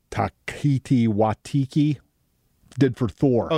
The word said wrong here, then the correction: Taika Waititi: Oh, Taika Waititi.